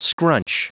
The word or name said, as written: scrunch